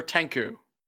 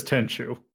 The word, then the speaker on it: Tenchu: Okay, but is it Tenchu or Tenku? It that one I always says Tenchu. – Yeah, I always knew it as Tenchu.